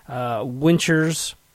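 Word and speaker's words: Wichers: I believe the last name is pronounced Winchers.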